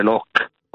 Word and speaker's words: Locke: Non so, mi vengono in mente, un autore, Locke, o Locke, come lo volete pronunciare, è inglese, no?